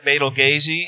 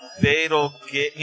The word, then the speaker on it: Betelgeuse: Yeah, so it's because I think that's actually it's actually pronounced like Betelgeuse,